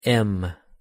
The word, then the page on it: m: English